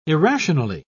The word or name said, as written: irrationally